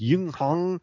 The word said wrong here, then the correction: Yihuang: and Yaxi province, is encouraging. I know I pronounced those wrong. I'm sorry. Don't come for me. – Yihuang and Jiangxi.